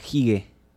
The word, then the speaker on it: hygge: No, hace un par de años ya conozco la palabra hige ¿Sabes lo que significa? – No, por supuesto no. – Es una palabra danesa, se escribe H-Y-G-G-E.